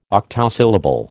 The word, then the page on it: octosyllable: American English